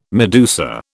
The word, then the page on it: medusa: British English